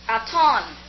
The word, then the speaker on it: Hatonn: Hatton, H-A-T-O-N-N. No two T's. My name is pronounced Aton. The H is a silent sound.